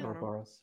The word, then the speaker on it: Ouroboros: Ouroboros? – I'm not sure how to pronounce it. – Ouroboros, yeah.